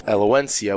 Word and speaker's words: Elegancia: And then number 3 cigar of the year is the Elegancia, which a lot of people, when you look at it, it looks like it says El Gugunis, but it's actually pronounced Elegancia, which is the Maduro Elegancia Robusto, which is number 3 cigar of the year.